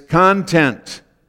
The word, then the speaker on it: content: So, if you put the emphasis on the first syllable, it's content.